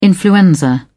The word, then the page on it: influenza: British English